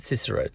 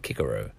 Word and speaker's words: Cicero: I heard that in ancient Latin he would have been called Cicero. But Cicero, they had a hard C back then, Cicero.